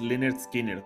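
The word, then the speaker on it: Lynyrd Skynyrd: Lineard Skinner su disco debut, Pronounced Leonard Skinner.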